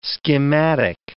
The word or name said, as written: schematic